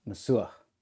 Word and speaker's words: Masuah: But I know that the actual pronunciation of my name is Masuah. – Masuah. Masuah. So, like, there's a guttural thing at the end, Masuah. – It's not a H, it's an Masuah.